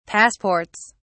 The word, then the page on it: passports: British English